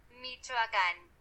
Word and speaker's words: Michoacán: Eu não sei se assim se pronuncia, então vou deixar aqui a pronúncia correta segundo o Google. – Michoacán.